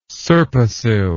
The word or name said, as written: serpasil